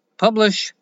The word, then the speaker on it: Publish0x: It is pronounced Publish, but it is spelled Publish0x.